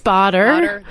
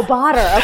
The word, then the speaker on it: Bader: I think batter, bader. We don't know. – I think it's botter. – Oh, botter. Okay. Okay. Good to know.